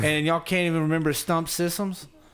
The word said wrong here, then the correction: Sisson: Sisson. – Sisson?